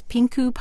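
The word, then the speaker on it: Pincu: At a place that I am going to pronounce Pinku Pottery. It's P-I-N-C-U.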